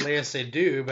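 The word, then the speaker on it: Léa Seydoux: I know how to pronounce Lea Seydoux, but I don't know.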